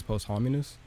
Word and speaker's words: posthumous: The first question we got is, how long after an artist's death would you think it's best to release a posthumous album? Is that how you say it? – I think it's posthumous.